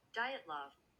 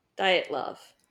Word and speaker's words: Dyatlov: I've been saying Dyatlov. I don't know if that's accurate. – I don't think it's Dyatlov. Because the L is after the T.